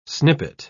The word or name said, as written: snippet